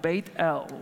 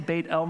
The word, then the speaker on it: Beit El: We're looking at the place in Genesis 35 of Beit El, or Bethel. I'll probably say Beit El most of the time because that's how it is in the Hebrew, and there's a reason I pronounce it that way as well.